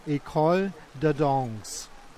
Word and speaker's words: École de Danse: Und das ist die École de Danse.